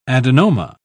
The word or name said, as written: adenoma